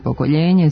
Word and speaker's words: pokoljenje: Ovo pravilo jedino ne važi za reč "pokolenje", koja nije glagolska imenica, već je izvedena od reči "koleno". Ona glasi "pokoljenje" samo u jekavskom izgovoru, jer je izvedena od reči "koljeno".